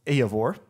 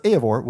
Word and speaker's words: Eivor: So it says that this not only features Faroese vocalist Eivor, that's what I was looking up at the beginning to how to say her name because I wanted to be sure I could get it right. And so Eivor, which I will get to focus on her in a little bit.